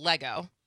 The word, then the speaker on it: Lego: So your big thing is still Lego.